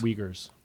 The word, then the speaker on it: Uyghurs: So wait, what are they called? They're called the Uyghurs? – Uyghurs. It's spelled like U-Y-G-H.